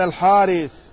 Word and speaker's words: الحارث: قال ابن خزيمة رحمه الله: قال حدثنا يحيى بن حبيب الحارثي قال حدثنا خالد يعني ابن الحارث عن محمد بن عجلان. لا، جرت العادة أن العرب يكتبون الحارث هكذا، وإن كتبوها بحذف الأليف لكنها تنطق بذلك.